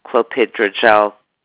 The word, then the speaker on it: clopidogrel: He is prescribed on clopidogrel, and atorvastatin, period. Clopidogrel, it's spelled C-L-O-P-I-D-O-G-R-E-L, and atorvastatin, period.